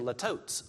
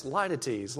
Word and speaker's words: litotes: And I, I, kept saying latotes, latotes when I was reading it. And then I went to the website where you type in the word and you just hit spell it, and it's litotes, litotes, litotes.